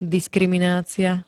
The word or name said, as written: diskriminacia